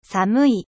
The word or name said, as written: froid